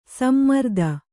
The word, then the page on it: ಸಮರ್ಥ: samartha